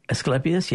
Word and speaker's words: Asclepias: And I'm going to slay the pronunciation of what is it, Asclepias? – Asclepias, yeah.